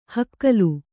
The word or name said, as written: ಹುಗಿಲು